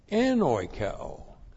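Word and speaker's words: Enoikeo (ἐνοικέω): E-N-O-I-K-E, long O, Enoikeo.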